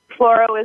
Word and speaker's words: Floro: Um, well, Floro is my last name, which is a very easy thing to rhyme with choreo.